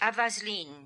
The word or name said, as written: Avazlin